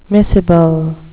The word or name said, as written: miscible